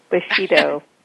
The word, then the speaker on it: Bushido: and Business Bushido. Oh my goodness, how do I say that? – Bushido.